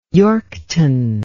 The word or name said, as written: Yorkton